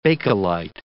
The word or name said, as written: Bakelite